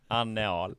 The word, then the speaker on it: Anne Ahl: Det är också så här, det uttalas ändå samma väl? – Ja. – Anne Ahl.